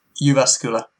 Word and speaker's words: Jyväskylä: Well, he, interesting fact, he's from Jyväskylä, Finland. Did I say that right? – Jyväskylä.